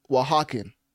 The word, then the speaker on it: Oaxacan: was finding out that O-A-X-A-C-A-N is pronounced Oaxacan. If you don't believe me, literally, it's spelled O-A-X-A-C-A-N, and you pronounce it, or supposed to pronounce it, Oaxacan.